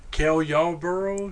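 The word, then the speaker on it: Cale Yarborough: I'm trying to kill Yarberg. – Who? – Who the fuck is that? – Kel Yarborough.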